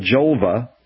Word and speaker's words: Jolva: and she has this thing called Jolva, rhymes with vulva,